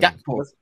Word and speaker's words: Gakpo: Gakpo. Yeah. – Yeah, What's come on. – Name? You can't just be skipping out a random like it's a K. There's no such thing as a silent K.